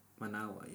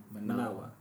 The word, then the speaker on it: Managua: Managua, yes. – The capital. Am I saying it wrong? Managua.